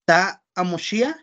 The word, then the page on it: ta amo xiaj: Náhuatl